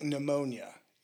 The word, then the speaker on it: pneumonia: Well, your spirit is something that in the Greek word is translated as it's pneuma, and it's spelled with a P. – Mm-hmm. – So pneuma, like pneumonia. If you ever wondered why pneumonia is spelled with a P. – Yeah, that always threw me off. – And you don't pronounce it.